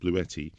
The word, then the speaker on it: Bluetti: It's B-L-U and then E-T-T-I. So it's pronounced Bluetti.